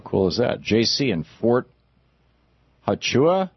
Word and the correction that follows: Huachuca: Is that how you say it? Hey, JC. – Yes, Huachuca. It's kind of difficult to Okay. Huachuca. Okay.